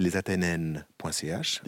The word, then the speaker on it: Athénennes: Il y a un site qui s'appelle lesathénennes.ch. – Difficile à dire. Hein. – Les Athénennes, oui, il faut prononcer le E aigu correctement.